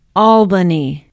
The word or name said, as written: albany